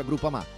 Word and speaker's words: Groupama: Groupama, si pronuncia Groupama, di Paolo Felicetti.